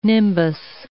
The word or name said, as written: nimbus